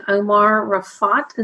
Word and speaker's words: Omar Raafat: Hi, it's great to be today with Omar Raafat.